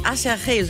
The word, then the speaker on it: Acherche: Lass Ketchup schon von einer Combo namens Acherche, so wird die ausgesprochen, aber es sieht aus wie Acherje, so kann man sich das auf jeden Fall gut merken.